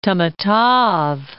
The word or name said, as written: Tamatave